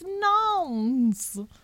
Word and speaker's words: Nantes: How to pronounce Nantes. Apparently it is Nantes.